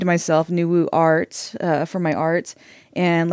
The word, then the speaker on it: Nuwu: So, can you tell me about Nuwu? – Yes, thank you. Am I pronouncing it correctly? – Yes, Nuwu.